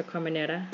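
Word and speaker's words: Carménère: I've lately been into Carmenere's or Carmenera, if you're gonna do it from the Spanish pronunciation.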